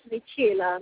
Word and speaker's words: Michaela: First name is Michaela, last name is Goem, and that is spelled like Michael, so M-I-C-H-A-E-L-A.